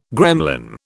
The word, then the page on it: gremlin: British English